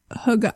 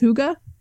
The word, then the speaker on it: hygge: Literally H-O-O-G-A. Hygge. – Hygge. – How the Danish pronounce it. Well, I would just like late breaking news here. Do you agree it might actually be pronounced hygge?